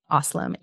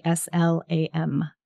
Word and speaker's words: Aslam: Aslam, Aslam.